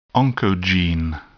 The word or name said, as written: oncogene